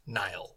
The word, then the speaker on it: Niall: So the character's name is Nile, which is spelled N-I-A-L-L. It's a Irish name.